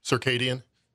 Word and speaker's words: circadian: Circadian rhythms? Is that how you say it? What does that even mean? – Circadian? – Yeah, circadian.